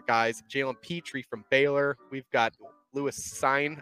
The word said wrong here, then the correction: Cine: I hope I said that right. I always get Cine. Lewis Cine. Cine.